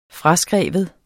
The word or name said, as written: fraskrive